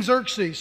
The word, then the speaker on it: Xerxes: How do you pronounce this king's name? Well, you can just call him King X if you want to. But it's called Xerxes, King Xerxes. And his part in the story was that he had a queen.